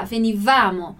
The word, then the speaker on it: venivamo: Como é que eu sei que o acento que aqui não existe, mas que a sílaba forte vai ser no vá, venivamo.